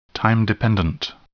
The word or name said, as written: time-dependent